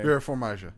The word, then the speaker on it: Vera Farmiga: Well, Vera Farmiga? I have no idea how to say her name. – Vera Farmiga.